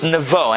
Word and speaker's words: Nebo: In the English transliteration, I think it becomes Nebo. Not to be confused with the fish. It's Nemo. Okay, so Har, but it's in the Hebrew, it's Nebo, and I'll tell you how it's spelled. It's spelled Nun, Vet, and Vav. Nebo. That's the mountain.